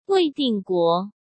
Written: Wei Dingguo

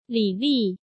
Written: Li Li

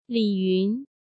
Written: Li Yun

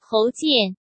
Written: Hou Jian

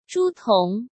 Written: Zhu Tong

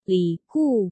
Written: Li Gu

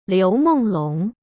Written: Liu Menglong

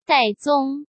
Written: Dai Zong

